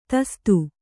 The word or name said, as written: ದೋಸ್ತ